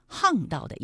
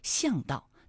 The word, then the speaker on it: 巷道: 另外还有一个读音啊，是巷，和昂巷，就是巷道的意思。那其实在读巷道的时候，可能有些朋友就会误读为巷道，那其实是不对的啊。